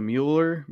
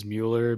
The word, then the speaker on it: Mueller: I think the general pronunciation is Mueller, but if you want to be like real... If you want to be real about it, it's Mueller. – Okay.